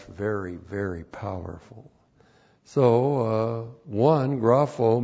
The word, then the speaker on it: grapho: G-R-A-P-H, long O, grapho.